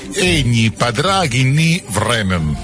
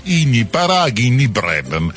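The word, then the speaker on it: Eithne Pádraigín Ní Bhraonáin: Egni Padraghi Ni Vremen, dovrebbe essere così, è scritto completamente diverso, ve lo leggo come è scritto: Eitne Padraghi Ni Braunain, e si dovrebbe pronunciare Igni Padraghi Ni Vremen.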